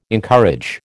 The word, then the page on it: encourage: American English